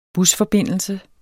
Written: busforbindelse

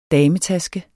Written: dametaske

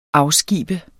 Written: AFSKIB

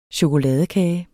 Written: CHOKOLADEKAGE

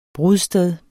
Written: brudsted

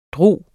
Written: drager

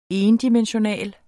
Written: endimensional

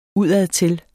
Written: udadtil

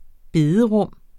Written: bederum

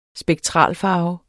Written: spektralfarve

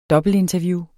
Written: dobbeltinterview

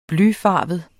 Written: blyfarvet

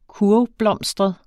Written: kurvblomstret

